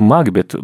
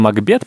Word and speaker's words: Макбет: Насколько я понимаю, ударение на первый э, слог сместилось из-за того, что и Гамлет сначала был Гамлет, угу. а потом как бы он стал Гамлетом, и Макбет тоже решили также произносить.